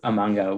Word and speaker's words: manga: But I mean, I think that's sort of the benefit of having this based off of a manga where like it is, there is going to be that expectation.